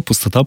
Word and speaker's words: Пустота: Читается корректно, как обычное слово «пустота», потому что речь идет об абсолютной пустоте в данном романе, и это является еще и фамилией главного персонажа, Петр Пустота.